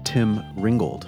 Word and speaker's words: Tim Ringgold: Our guest for this episode is Tim Ringgold.